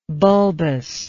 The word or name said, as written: bulbously